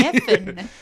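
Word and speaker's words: Cynefin: Kinefin. Can you spell that if we're going to Google it? – It's C-N-E-F-I-N. So C-N-E-F-I-N. So it doesn't sound like it sounds. I believe it's a Welsh word.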